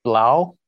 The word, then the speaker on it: 3LAU: I believe it's pronounced Blau. It's written 3-L-A-U.